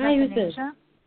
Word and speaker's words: Athanasia: Hi, this is Athanasia.